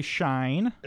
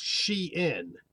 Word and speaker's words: Shein: Okay, another word that I think is probably a name, and I also don't know how to pronounce, S-H-E-I-N. I would look at that and probably say shine. – And that's what I would have said. – Okay. Right. And who is this and how do you say it? – They say it should be pronounced Shein.